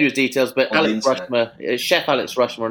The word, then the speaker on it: Rushmer: So, wow, Alex Rushler, I'm looking forward to getting in touch with him and getting him on. – Yeah. – Sorry, can you spell it then? – R-U-S-H-M-E-R, Alex Rushmer.